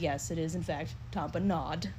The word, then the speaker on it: tamponade: But he pronounced it tamponading. And that's wrong. I looked it up to verify. Because I was like, wait, Grey's Anatomy calls that a tamponade.